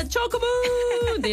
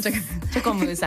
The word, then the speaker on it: チョコムーン: 発音はチョコムーンでいいのかな。 – 合ってます。 – チョコムーンさんです。すみません、あの英語で CHOCOMOO、チョコムーンさんです。おはようございます。